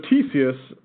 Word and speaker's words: Ctesias: So what did you say? – Ctesias. Wow. It's, a silent C.